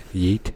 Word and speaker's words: yeet: Did I say that right? Yeet? – Yeah, yeet.